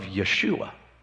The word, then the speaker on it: Yeshua: With joy you will draw water from the wells of Yeshua. Yeshua is Jesus' Hebrew name. It's how you say his name in Hebrew.